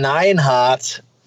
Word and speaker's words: Neidhart: Jim the Anvil Neidhart. Ja. Nå, det er sådan, det udtales.